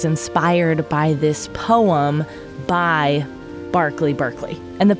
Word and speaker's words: Berkeley: In England, that particular construct, that ERK, is uh, often pronounced ARK. And there is also Berkeley Square in London, which is actually would look to us like Berkeley Square.